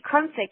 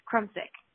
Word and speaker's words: Crumsick: Crumsick. It's like a crumbs and then you're sick, but you put them together and it's Crumsick.